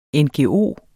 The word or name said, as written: ngo